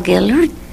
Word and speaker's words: Gehlert: My name is Rosa Gellert, G-E-H-L-E-R-T.